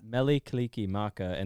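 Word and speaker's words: Mele Kalikimaka: Melikalikimaka, and that's by Bing Crosby in 1950. – Nice. Melikalikimaka by Bing Crosby.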